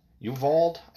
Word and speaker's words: Uvalde: U- U- Uvalde, Uvalde, I think is how it's, it's pronounced.